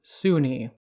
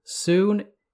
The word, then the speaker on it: Sune: Having read books from 2nd edition and 3rd edition, these books state that you pronounce the goddess's name Suni.